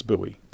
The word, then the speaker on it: Bowie: Like I tell my students every semester, I don't expect you to end up pronouncing my name right. It's fine. I will answer to either. But just so that you know, it's Bowie.